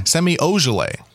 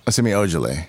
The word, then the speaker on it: Ojeleye: How do you say his name? – Semi Ojeleye. – Semi Ojeleye. – Yeah, right. There you go.